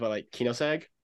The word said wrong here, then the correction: Koenigsegg: Oh, oh, the Koenigsegg, the Koenigsegg, yeah, Koenigsegg, the CCX.